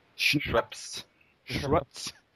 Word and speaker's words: Schoenaerts: Schoenaerts.